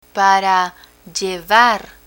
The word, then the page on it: para llevar: Spanish